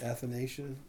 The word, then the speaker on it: Athanasian: Is it Athanasian? Is that how you pronounce that, Jennifer? – Yeah.